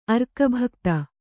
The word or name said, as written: ಅರ್ಕಭಕ್ತ